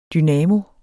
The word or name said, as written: dynamo